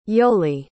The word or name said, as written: yoli